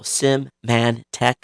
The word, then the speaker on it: Symantec: We got one from someone corrected me on the correct way to say Symantec. So Symantec.